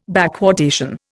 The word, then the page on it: backwardation: Indian